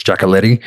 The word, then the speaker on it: Giacoletti: however different people pronounce it, but generally it's Giacoletti.